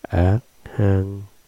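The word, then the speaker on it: Arhan: Đại A La Hán là tiếng Ấn Độ, nguyên âm đọc là Ác Hăng,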